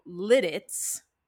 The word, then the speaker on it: Lititz: So Lititz, that's the official pronunciation.